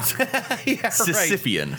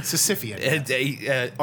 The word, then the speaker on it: Sisyphean: Sisyphean. – Sisyphean. – Arting a sandwich forever up a hill. – I think I added an extra syllable onto there. – Just drives me crazy. – Sisyphean.